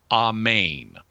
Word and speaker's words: Amen: Um, what I can say is that at least the way the word is represented in the Greek New Testament it should be pronounced something approximating Amen because it's spelled with a, an, an alpha as the first vowel, which is a short A, and an eta, which is a long A, as the second vowel.